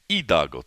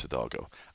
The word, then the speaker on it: Hidalgo: He is in Hidalgo, Texas, or as I've been told to pronounce it right, Hidalgo, Texas. But for us gringos, it's Hidalgo. I, on the other hand, am in San Luis Potosí, Mexico.